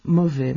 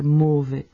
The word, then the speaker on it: mauvais: Pero quizás más fácil otro ejemplo, otro mauvais, malo, ¿no? En francés se pronuncia con una O muy abierta. En ciertas zonas de Bélgica es muy corriente oír mauvais, con una O muy cerrada.